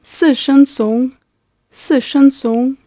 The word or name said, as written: sishencong